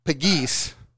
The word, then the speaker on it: Pegues: We got Brandon Pegues with a silent E on the end.